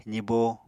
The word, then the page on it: zibo: apatani